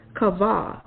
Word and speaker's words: kavah: And it says this comes from another root word, kavah, K-A-V-A-H, which means to bind together, collect, to expect, right?